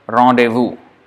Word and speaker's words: rendezvous: Okay, this is coming from the French language, and then it is to be pronounced as rendezvous, rendezvous. So you would not know, but the spelling and the pronunciation has no relation almost sometimes.